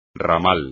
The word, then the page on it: ramal: espagnol